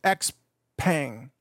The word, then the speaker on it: Xpeng: So first, I'm going to play a video for you about the announcement from a company that in English, we pronounce it Xpeng, I think, X-P-E-N-G, although I think in Chinese that would normally be Peng.